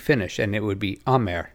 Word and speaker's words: Amer: The owner of Mavic is Amer? What? How do they say that? – I think most properly it's Amay.